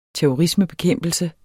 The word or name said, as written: terrorisme